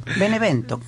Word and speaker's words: Benevento: Victoria 4-1 de la Fiorentina frente al Benevento. No sé si lo estoy pronunciando bien. – Benevento, no. Sí. – Benevento. – No, ahí lo pronunciaste mal. Benevento. Normal.